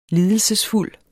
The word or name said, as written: lides